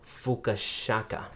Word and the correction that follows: Fukasaku: Fukasaku? – I think it's Fukasaku. – Fukasaku. – Yeah.